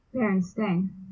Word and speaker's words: Berenstain: Berenstain.